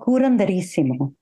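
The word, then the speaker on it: curanderismo: It relates to like personal meditation practices, but also comes from some traditions and rituals that you glean from, like, I'm going to say this wrong, curandísima, the healing. – So it's called curanderísimo.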